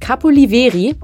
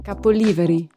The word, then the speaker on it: Capoliveri: Auf Elba gibt es einen Ort, den ich immer falsch ausgesprochen habe, nämlich so wie Deutsche es aussprechen würden, nämlich Capoliveri. Ist falsch ausgesprochen, ich sag's euch nur schon mal. Und dann hat mir eine super nette Einheimische gesagt, wie man es wirklich ausspricht. Und jetzt hört euch das an.